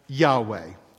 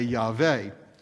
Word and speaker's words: Yahweh: God's proper name, then, is pronounced Yahweh. Somehow, I got in the habit of pronouncing it the way that the Germans do, with the W taking the sound of our V, so I often say Yahweh.